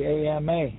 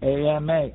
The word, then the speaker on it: ama: It's spelled A-M-A. And look at the problems today with the AMA. The doctor bills are so high, people cannot afford them. AMA, AMA, is water in Cherokee.